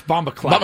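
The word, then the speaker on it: bombaclat: It's Bomba Clot. – Bomba Clot. Yeah, C-L-A-T.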